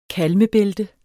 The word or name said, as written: kalmebælte